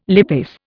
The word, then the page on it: lipase: Indian